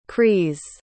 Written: kreis

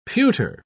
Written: pewter

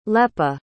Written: lepa

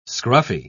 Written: scruffy

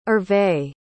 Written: herve

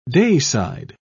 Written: dayside

